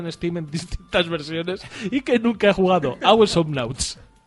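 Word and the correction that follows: Awesomenauts: ¿Cómo lo has pronunciado? Perdona. – Ah, awesome Nauts.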